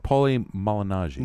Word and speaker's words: Paulie Malignaggi: I say his name for me again. – Malafala. No. Paulie Malignaggi.